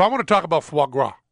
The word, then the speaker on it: foie gras: When I see the foie gras here, F-O-I-E-G-R-A-S, but my wife wrote it down phonetically for me. I have it on my computer, F-W-A-G-R-A-H, foie gras.